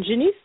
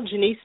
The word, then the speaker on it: Janice: And I am Janice Tennell, Janice spelled like Janice, J-A-N-I-C-E, last name Tennell, two N's, two L's. – And it's T-U-N-N-E-L-L? – Yes.